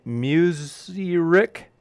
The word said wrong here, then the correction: Mushrik: M-U-S? – Mushrik? – That's right. Thank you.